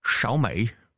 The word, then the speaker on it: Xiaomi: Og de hedder, og jeg tror, det er udtalt Xiaomi. – Xiaomi. – Xiaomi. – Xiaomi. – Er det sådan, man siger det? – Ja, det er det faktisk.